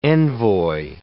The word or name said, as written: envoy